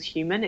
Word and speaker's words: HVMN: So for endurance athletes, I'm working with a company at the moment called Human. It's spelled HVMN,